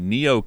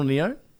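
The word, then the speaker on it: Nioh: this week, I've been playing the Neo Collection.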